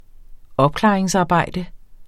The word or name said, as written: opklaringsarbejde